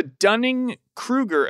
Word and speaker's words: Dunning-Kruger: It's the Dunning-Kruger effect.